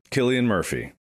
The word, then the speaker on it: Cillian: Killian Murphy. – Hey Siri, how do you pronounce Killian Murphy's name? It is with a hard C. It's Killian Murphy.